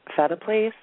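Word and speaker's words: Phetteplace: Kim Marie Fetaplace.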